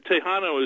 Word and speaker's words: Tejano: Here's one that's specifically terrific for pork, and I hope I'm pronouncing, is it Tejano, right? – Right, and Tejano is just the word for Tex-Mex in Spanish.